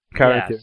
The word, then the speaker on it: Caranthir: Caranthir, yeah. Caranthir, yes.